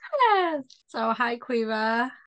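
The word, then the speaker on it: Caoimhe: She does have a traditional Irish name that us Brits look at it and go, Kayomi? Because until I was like heard how it was actually pronounced, that's how I would have pronounced it. It's Kaomihe, and you pronounce it Quiva.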